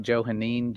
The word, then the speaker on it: Johannine: It's Joe, if I'm saying it right, Johannine, J O H A N N I N E.